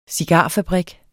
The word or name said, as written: cigarfabrik